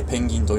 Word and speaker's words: 人鳥: ね、漢字で書くと人の鳥と書いて、人鳥と書いてペンギンと読みます。